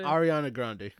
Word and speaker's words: Ariana Grande: In 2019, this year, 2019, you will be dancing to why did I forget how to say her name? Adria? Audria? Andrea? – Who the hell are you talking about? – Grande. – Oh, Ariana Grande? – Ariana Grande. You'd be dancing to an Ariana Grande song.